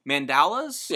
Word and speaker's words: mandalas: Specifically coloring, like, I don't know if I'm pronouncing this correctly because it's me. Mandalas? – Yes, yeah. That's how you say it.